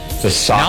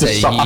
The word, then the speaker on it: Sasaki: Sasaki. – Not like that. I'll tell you, that's Sasaki. You don't say it like that.